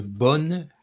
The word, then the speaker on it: bonne: But if you like the chair you're sitting in, it would be une bonne chaise.